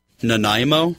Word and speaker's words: Nanaimo: Oh, Nanai. Well, I don't know how to say it in Canadian, but Nanaimo? – Yes. – Nanaimo.